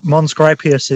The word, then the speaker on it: Mons Graupius: He's also the author of Fortress Scotland, Edinburgh Castle and the Romans in Scotland and the Battle of Mons Graupius. And I do apologise if I've pronounced that terribly. So perhaps, Simon, you'd like to correct my awful pronunciation. – No, that Mons Graupius is correct. That's fine.